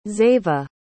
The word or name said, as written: xava